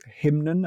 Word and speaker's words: Hymnen: And then in the next chapter, we're in a different place again, and we're looking at Karlheinz Stockhausen's piece Hymnen, I think it's pronounced. – That's right, yes.